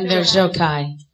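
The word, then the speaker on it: Enver Gjokaj: Enver Jokai.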